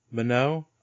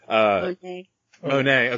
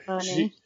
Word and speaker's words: Monáe: So of course, one of the things we talked about was Janelle Monáe. I know I'm saying that wrong. – Monáe. – Okay. Monáe. She's put her ring in.